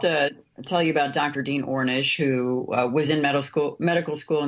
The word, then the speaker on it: Ornish: My question for you, the Amish, I hope I'm pronouncing that right, Ornish, thank you, reversal program at Beacom Health that Dave participated in is a program featured in the documentary Optimizing Life.